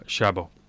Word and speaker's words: Chaabo: And Sister Sarah Chaabo.